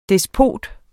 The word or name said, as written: despot